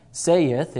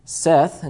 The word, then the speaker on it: saith: Now that word, I like to say it saith, but someone told me that that word's actually pronounced Seth. You take the TH off of that, and you put a D on there, you get the word said. And then you take that off, it's not sayed, it's said. And so you put the TH on there, it's not saith, it's Seth.